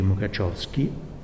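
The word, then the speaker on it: Mukařovský: Gli altri che ho menzionato, Pietr Bagatiriev, scritto Petr Bogatirev, Roman Jakobson e Ian Mukachovsky, che è scritto Mukarovsky, ma credo che si pronunci Mukachovsky,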